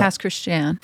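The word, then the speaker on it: Pass Christian: Past Christian. – Very rough name for me. – It's the Cajun pronunciation of Christian.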